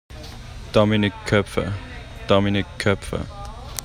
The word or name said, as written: Dominik Koepfer